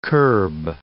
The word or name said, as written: curb